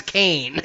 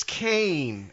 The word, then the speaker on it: Kaine: I think it's supposed to be like Raisin Kane. – Oh, yeah, okay. All right. I don't know where you got. I said Hazen Khan, but we don't ever hear anyone say his name, right? – It's spelled K-A-I-N-E. In English, right. – That's Kane. – It's Kane.